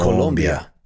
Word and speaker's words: Colombia: It's not Colombia. Colombia. Colombia is how you would say it correctly.